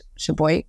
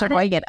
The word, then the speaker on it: Sheboygan: Correct me if I'm wrong. Sheboygan. – Sheboygan. You got Sheboygan.